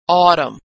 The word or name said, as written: autumn